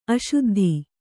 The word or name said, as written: ಅಶುದ್ಧಿ